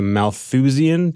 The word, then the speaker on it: Malthusian: I want to start with an article you wrote a few months ago called, I think, The Attention Economy is a Malthusian. Did I pronounce Malthusian correct? – I think you did. That's Trap. – How I pronounce it.